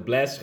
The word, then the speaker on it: Blxst: Also Blast schreibt man mit B-L-X-S-T.